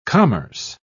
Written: commerce

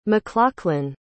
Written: mclachlan

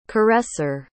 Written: caresser

